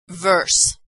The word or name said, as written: verse